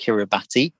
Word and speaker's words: Kiribati: If you were to write it down, it looks like it's pronounced Kiribati, but it's pronounced Kiribati, which I've got wrong for a very long time.